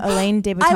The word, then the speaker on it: Alain de Botton: Alain de Botton.